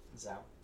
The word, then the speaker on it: zao: Greek word zeo Am I pronouncing that right? Zao.